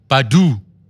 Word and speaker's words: Badoo: Badoo. – Is it? – Like, as in Erica. – But it's spelled B-A-D-O-O.